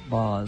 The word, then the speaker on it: Baz: You know, I hate to tell you this, but he does pronounce it Boz.